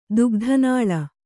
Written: ದುಗ್ಧನಾಳ

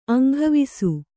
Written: ಅಂಗವಿಸು

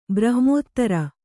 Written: ಬ್ರಹ್ಮದಾರು

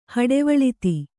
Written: ಹಡೆವಳಿತಿ

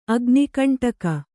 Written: ಅಗ್ನಿಕಂಟಕ

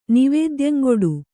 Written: ನಿವೇದ್ಯಂಗೊಡು

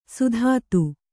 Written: ಸುದತಿ

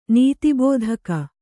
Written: ನೀತಿಬೋಧಕ

